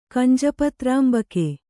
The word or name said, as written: ಕಂಜಪತ್ರಾಂಬಕೆ